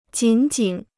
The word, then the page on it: 紧紧: jǐn jǐn